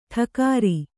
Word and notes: ಠಕಾರ: ṭhakāra

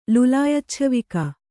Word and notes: ಲುಲಾಯಚ್ಛವಿಕ: kannada

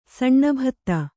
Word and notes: ಸಾನುಭೂತಿ: sānubhūti

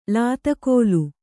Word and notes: ಲಾತಕೋಲು: Kannada

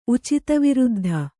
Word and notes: ಉಚಿತವಿರುದ್ಧ: Kannada

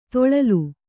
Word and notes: ತೊಳಲು: toḷalu

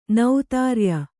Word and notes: ನೌತಾರ್ಯ: nautārya

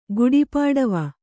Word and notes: ಗುಡಿಪಾಡಿವ: kannada

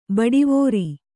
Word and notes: ಬಡಿವಾರ: Kannada

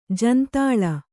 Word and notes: ಜಂತಲ: jantala

